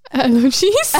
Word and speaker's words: elegies: Is it romantic how all of my alleges... – Elegies? – Elegies. – Elegize me. – Taylor Swift. – Elegies. How do you say that? – Elegies.